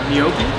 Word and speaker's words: gnocchi: They are the pasta gnocchi.